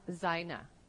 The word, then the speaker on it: zaina: The word for tactics is pronounced sanatha, and the word for armor is zaina.